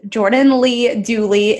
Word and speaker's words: Jordan Lee Dooley: So you can find me on all the social media platforms, Jordan Lee Dooley. It rhymes. So people say I have a Dr. Seuss name.